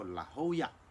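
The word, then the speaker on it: La Jolla: 所以我接待了很多的朋友，那么这些朋友来的时候呢，我一定会跟他们分享一个经验，我会带到圣地亚哥最有名的海边，叫做拉霍亚，那是一个西班牙文的发音，如果你看英文的话，拼音拼出来不是这样，可是因为它是西班牙发音，所以我们大家都知道拉霍亚。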